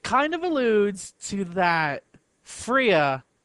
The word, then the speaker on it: Freya: It's pronounced Freya, yeah.